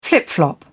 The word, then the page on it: flip-flop: British English